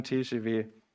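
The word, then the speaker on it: TGV: aber TGW schreibt man eigentlich mit V, das spricht man nur W aus.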